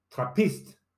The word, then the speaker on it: Trappist: By the way, in Flemish and Dutch, the Trappist name is pronounced Trappist, with the emphasis not made on the A, but on the I.